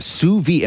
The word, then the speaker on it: sous vide: Sous vide, and it's spelled S-O-U-S-V-I-D-E. Sous vide. I'm telling you, it's a way of cooking. People cook like this, and they've been sous weed. – Yes, sous. No, it's not called sous vide.